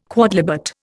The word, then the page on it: quodlibet: Indian